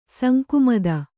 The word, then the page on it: ಸಂಕುಮದ: sankumada